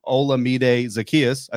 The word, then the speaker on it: Olamide Zaccheaus: Look, the rest of the Falcons team this season, 25 catches, 370 yards, only one touchdown, which came to Olamide Zaccheaus. I said that correctly.